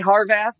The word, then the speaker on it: Harvath: And so, you know, Harvath, is that how you say his last name? – I say Harvath.